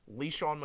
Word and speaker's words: LeSean: LeSean McCoy. – I'm sorry, how did you just pronounce his name? – LeSean McCoy? – LeSean? – LeSean McCoy.